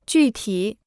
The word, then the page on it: 具体: jù tǐ